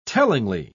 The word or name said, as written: tellingly